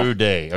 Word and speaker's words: Amude: Is it Stanley Amude from South Dakota? Is that how you put it? – Amude. – Okay, I figured there was probably emphasis on the E.